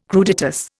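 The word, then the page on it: crudités: Indian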